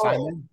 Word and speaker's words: saimin: Saimin? – Okay.